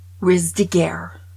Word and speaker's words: Ruse de guerre: That's how you say it. Watch this. – French. Riz de Guerre. French. Riz de Guerre. French. Riz de Guerre. French. – See? – Riz de Guerre. – It's not even hard. But Americans can't speak French. They won't use their accent.